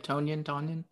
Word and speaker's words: Tonyan: Tonyan.